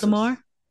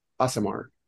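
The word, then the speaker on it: Aasimar: ASMR? – Azamar. – Okay, because I immediately thought of ASMR, and I'm like, that's not how you say it.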